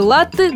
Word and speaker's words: латте: Ударение в слове «латте» должно ставиться исключительно на первый слог и никак не на последний.